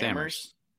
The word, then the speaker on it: Amherst: Just doing us dirty by pronouncing it wrong. – Amherst? – It's Amherst. Amherst. Yeah.